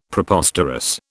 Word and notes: Preposterous: British English

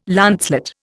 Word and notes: lancelet: Indian

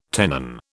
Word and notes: tenon: British English

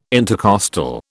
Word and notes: intercostal: British English

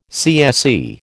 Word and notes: CSE: American English

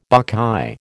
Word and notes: buckeye: American English

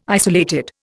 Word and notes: isolated: Indian

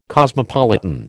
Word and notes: cosmopolitan: American English

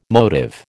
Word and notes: motive: American English

